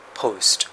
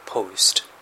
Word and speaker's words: post: post。これ二重母音ですね。 post ではなくて post。